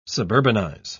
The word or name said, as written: suburbanize